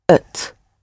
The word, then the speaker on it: het: The word for it is het, spelled H-E-T. But in everyday colloquial language, it is usually pronounced ut. Ut. So your mouth is sort of in neutral, if you like.